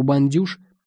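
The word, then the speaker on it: Bains Douches: У «Бандюш», как его произносили, на старых каменных ступенях во всякое время ночи всегда находилась толпа странных персонажей, желающих попасть в нестандартный этот рай.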